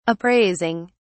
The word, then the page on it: appraising: British English